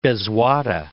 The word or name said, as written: Bezwada